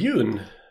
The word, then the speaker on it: Yuen: Det är Corey Yoon. Jag vet inte om man uttalar Yuen, Yun. – Jag tror du var rätt från början.